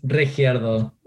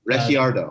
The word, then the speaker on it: Regiardo: Um, today's guests, we have Samantha Trottier and Hernan uh, Rehario. – Yeah. – And that they one. are both, tell me it again. How do you say it? – Uh, well, it's Regiardo. – Regiardo.